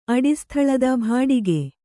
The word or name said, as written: ಭಾಡಿಗೆ